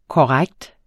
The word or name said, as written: korrekt